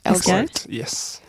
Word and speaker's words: Elgort: Thief av Ansel Elgort. Du uttalar det rätt? – Elgort, yes.